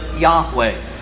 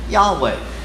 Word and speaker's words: Yahweh: We believe that to be very close to the pronunciation of God's divine name, Yahweh.